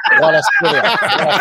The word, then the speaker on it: Kvaratskhelia: Kvaratskhelia? – Kvaratskhelia.